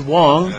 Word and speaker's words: Wang: It is pronounced Wong, although it is spelled Wang.